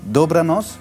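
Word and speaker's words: Dobranoc: Dobranos. – Sehr gut. – Stimmt so? Ja. – Hast du auch richtig gut ausgesprochen, als wenn du wirklich aus Polen kommen würdest.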